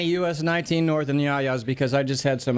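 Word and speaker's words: Yia's: And the name is actually pronounced Yaya's Greek Express, even though it's spelled Y-I-A apostrophe S. That is the Greek way to spell Yaya.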